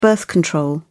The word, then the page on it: birth control: British English